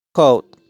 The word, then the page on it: Coat: /koʊt/